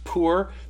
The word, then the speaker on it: pur: The lot, or poor, it's P-U-R, it's pronounced poor. The plural is purim.